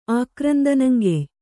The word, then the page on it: ಆಕ್ರಂದನಂಗೆಯ್: kannada